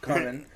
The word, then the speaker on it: Carmen: It's like Charmin. Carmen. – Carmen. It's spelled with an I.